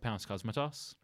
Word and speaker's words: Panos Cosmatos: So I'm saying that right, Panos Cosmatos. – Yeah.